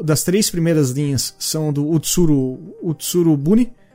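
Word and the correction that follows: Utsuro-bune: Como fala, fala Vanoro? Utsuru Bune. Utsuru desculpa, eu sabia que eu tava falando errado.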